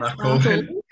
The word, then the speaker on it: Barcaldine: Barcaldine, I think you pronounce it, not Barcaldine. – No, it's Barcaldine.